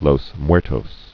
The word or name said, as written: Dia de los Muertos